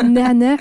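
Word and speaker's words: Nahna (نعناع): C'est Nahna, mais j'ai moi-même un accent français, donc.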